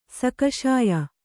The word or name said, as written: ಸಾಕ್ಷ್ಯ